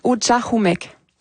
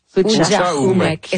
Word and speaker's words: Ujahumek: On le prononce Ujahumek. – Ujahumek.